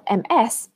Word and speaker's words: Ms.: So remember, although it's spelled MS, it's pronounced Ms. Ms. Webster.